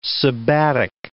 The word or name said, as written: sabbatic